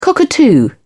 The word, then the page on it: cockatoo: British English